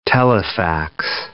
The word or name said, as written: telefax